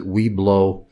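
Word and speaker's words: Webelos: I was a Cub Scout and a Weblo, or as some pronounce it, Weblo.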